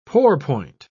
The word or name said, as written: pourpoint